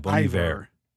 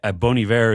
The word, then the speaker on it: Bon Iver: Yeah, Bon Iver.